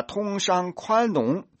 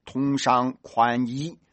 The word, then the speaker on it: 通商宽容: 把通商宽容、字正腔圆、稳稳当当地读成了通商宽衣，从而成为全中国和全世界的笑柄。